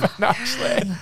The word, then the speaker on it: pinochle: Pinochle. – That's awful. – It's pronounced the way it looks.